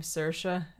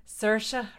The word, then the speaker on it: Saoirse: It's like the Irish name Saoirse. – Mm-hmm. – Saoirse rhymes with inertia.